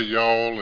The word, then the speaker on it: y'all: and if they make a two-syllable word out of it, I know I'm in the wrong place. So it's neat to be here and know that hear y'all say y'all, and it's a one-syllable word, and you know what that is.